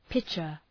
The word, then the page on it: pitcher: ‘pıtʃər